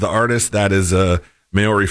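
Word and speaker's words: Māori: Stent said, and I had to look up how to say this because it looks like it's in Maori, whatever that. – Maori.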